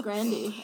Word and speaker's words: Grande: That's actually how you pronounce her name. I know it is. It's Grande. Everyone's been saying it wrong, including me.